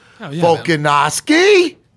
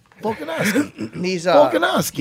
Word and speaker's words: Volkanovski: He looks the part, Alexander Volkanovski. – Yeah. – Don't say it like that. Volkanovski.